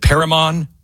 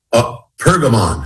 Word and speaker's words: Pergamon: It's called The Altar of, is it Perigmon? Perigmon? How do you say it? – Pergamon.